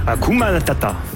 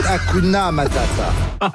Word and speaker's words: Hakuna Matata: Hakuna Matata. – Non, c'est Hakuna Matata. – Hakuna Matata. – Mais non, mais oui, mais. – Oh, le bâtard, il nous fait perdre la cause. – C'est Hakuna Matata.